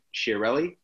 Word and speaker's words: Chiarelli: So your name, Ben Chiarelli? – Chiarelli, and it's spelled C-H-I-A-R-E-L-L-I.